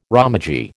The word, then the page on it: romaji: American English